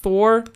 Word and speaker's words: Thor: Wird doch auch Thor ausgesprochen, ja, oder? Ja, doch, doch. Thor, Thor.